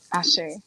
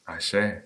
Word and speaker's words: Ase: So, Ashe. – Ashe. Say it with me, y'all. Say it in the chat. – Ashe. – And you spell it A-S-E, right? – It has, yes, A-S-E or A-S-H-E.